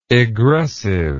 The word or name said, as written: egressive